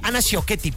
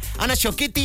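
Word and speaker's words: Ana Ciocchetti: Aparecen por allí en escena Ana Coschetti, Isabela Camil, Ana Sciocchetti, perdónenme, lo dije mal. Ana Sciocchetti, Isabela Camil y Sabine Mussier.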